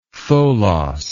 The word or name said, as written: tholos